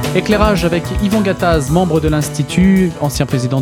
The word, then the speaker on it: Gattaz: Gattaz, c'est toujours bien prononcé Gattaz puisque je suis né à Bourgoin, entre Lyon et Grenoble, dans l'Isère, donc dans le Dauphiné.